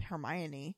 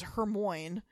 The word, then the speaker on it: Hermione: In Harry Potter, I used to think I'd never heard the word named Hermione. And so she was Hermoine for like ever until I saw the movie.